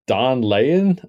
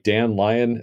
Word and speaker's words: Daan Leijen: But it's like Don Lyon or like Dan Lyon is how I might pronounce it. D A A N L E I J E N.